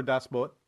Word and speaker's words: Das Boot: We're getting good feedback on the Twitter from the various members votes for Das Boot. Actually, I believe it is spelled boot, B-O-O-T, but I believe it is not pronounced with the long oo.